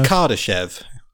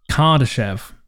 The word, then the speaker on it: Kardashev: Did I pronounce that right? Yeah. – Kardashev. I think it's Kardashev. – Kardashev.